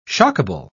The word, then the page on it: shockable: British English